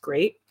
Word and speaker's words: GLEAT: It's spelled GLEAT, but it's pronounced Great.